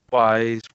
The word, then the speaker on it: Ys: And I finally settled on my pronunciation of this word because I didn't know if it was like I's, Y's, Y's, but apparently it's YS.